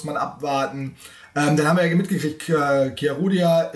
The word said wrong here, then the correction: Chiarodia: äh, sag mal mal, wie wird der richtig ausgesprochen? Chiarodia. – Sehr gut.